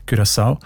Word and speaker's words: Curaçao: Habe ich gelernt, spricht man eben mit «ü» aus, wegen, weil Holländisch dort auch eine wichtige Sprache ist. Und im Holländischen ist das «u» offenbar häufig als «ü», Curaçao.